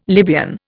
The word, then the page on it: Libyan: Indian